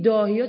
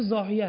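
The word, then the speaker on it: ضاحیه: این داهیا چون انگلیسیشو خوندن عزیزانی که مثلا حالا یک جایی مطرح کردن نمیدونن، این همون واهی است، چون عرب با وا اینطور تلفظ میکنه اینا د میشنون، یعنی انگلیسیشو د میگه مینویسن. خب این داهیا نیست، دکترین زاهیه است.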